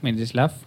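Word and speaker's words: Mstislav: Mislav, no, ¿cómo se dice? – Mitislav. Mistislav.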